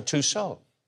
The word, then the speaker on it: Tussauds: Is it pronounced Tussauds or Tussauds? – Tussauds. – Tussauds. – The French leave off a couple letters at the end of each word.